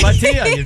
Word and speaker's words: Bhatia: Yes, Bhatia, you nailed it.